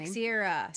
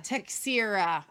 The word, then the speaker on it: Teixeira: We cannot pronounce his last name. Teixeira. – Teixeira.